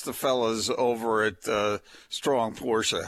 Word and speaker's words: Porsche: Porsche. – It's pronounced Porsche. Porsche. – It's okay. – The only thing worse than someone owning a Porsche is calling it Porsche.